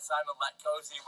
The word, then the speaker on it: Simon Latkoczy: I've got Simon Latkosi with me, a Slovakian.